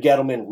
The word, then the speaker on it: Gettleman: You had David Gettleman. Did I say that right? David Gettleman. Yeah, yep. David Gettleman.